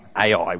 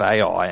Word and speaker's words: Ai: And then next city on the list is Ai, which I think is how it's pronounced.